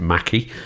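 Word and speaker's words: Mackey: So it features an up-and-coming star who's Emma, I think you pronounce it Mackie. – Yes, but, from Sex Education.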